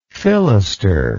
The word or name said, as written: filister